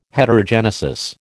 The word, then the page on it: Heterogenesis: American English